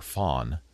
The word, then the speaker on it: FON: It's F-O-N is the router, FON. I think it's a Spanish company. That's why it's pronounced FON, F-O-N.